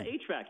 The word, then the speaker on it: HVAC: And we're talking about how you get, no, is it HVAC? How do we say that? We don't even have the heating. – Yeah, HVAC. You got it right.